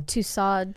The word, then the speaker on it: Tussauds: Madame Tussauds.